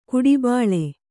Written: ಕಡುಬಲಿ

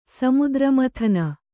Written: ಸಮುದ್ರಮಥನ

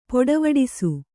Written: ಪೊಡೆವಡಿಸು

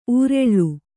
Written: ಉರಾಳ

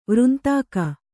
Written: ವೃಂತಾಕ

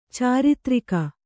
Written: ಚಾರಿತ್ರಿಕ

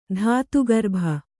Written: ಧಾತುಗರ್ಭ

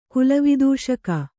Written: ಕುಲವಿದೂಷಕ

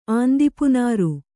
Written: ಆಂದಿಪುನಾರು

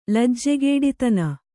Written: ಲಜ್ಜೆಗೇಡಿತನ

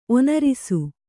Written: ಒನರಿಸು

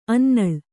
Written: ಅಣ್ಣೆನೂಲು

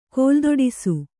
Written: ಕೋಲ್ದೊಡಿಸು

